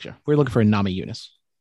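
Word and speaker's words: Namajunas: Is it it's a soft J? – Yeah, Nama Yunus. – Give you a soft J. – I always thought it was with a hard J. – Nama Junus? No, it's Nama Yunus.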